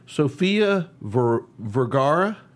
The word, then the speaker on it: Sofia Vergara: One of our favorite actresses, I know she's one of mine, and I'm sure she's one of yours, Sofia Vergara.